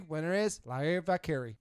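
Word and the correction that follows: Valkyria: It's Valkyra, but I've messed it up sometimes too. I was calling her Valkyrie for like the first couple weeks.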